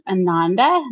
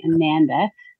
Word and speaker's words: Ananda: So it's actually pronounced Ananda, not Ananda. People think Ananda. It rhymes with Amanda, so they'll call me Ananda.